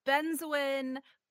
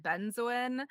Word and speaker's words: Benzoin: So this week, I'm talking about Benzoin. There seems to be no consensus on how to pronounce this. I've always said Benzoin.